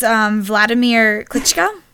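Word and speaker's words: Wladimir Klitschko: It's Vladimir Klitschko.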